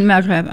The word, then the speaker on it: Maghreb: what, Maghreb, is that how you say it? – In Maghreb, uh-huh.